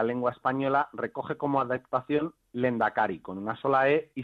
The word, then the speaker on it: lendakari: A partir de ahí, en realidad, si, cuando lo pronunciamos pronunciamos dos es, tampoco habría ningún problema en escribir leendakari en redonda con su H y sus dos es, eh, porque esa secuencia de e h -E, eh, también forma parte del español, aparece en rehén, por ejemplo, ¿no?